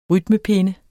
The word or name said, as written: rytmepinde